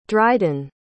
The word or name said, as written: driden